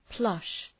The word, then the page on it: plush: plʌʃ